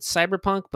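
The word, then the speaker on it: CBR+PNK: And there is a game that's nominated for several Ennies, I guess you would pronounce it Cyberpunk, but it's spelled CBR plus sign PNK. Cyberpunk.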